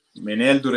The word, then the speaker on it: Meneldur: Ecco, c'era questo re, Meneldur, giusto l'accento? – Meneldur è giusto.